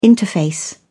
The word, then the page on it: interface: British English